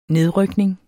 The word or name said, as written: nedrykning